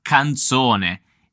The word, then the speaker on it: canzone: Attenzione alla pronuncia della parola canzone. In questo caso la Z è diversa da quella che abbiamo visto per azzurro. Infatti, nella parola azzurro abbiamo una Z sonora, quindi z, z, z.